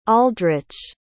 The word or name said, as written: aldrich